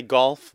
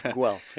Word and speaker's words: Guelph: A lot of people say golf, but it's Guelph.